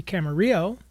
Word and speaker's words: Camarillo: That was Camarillo Brillo, and for all you folks that aren't from Southern California, it's actually Camarillo in Ventura County, but he wanted something to rhyme with Brillo, which means shiny in Spanish.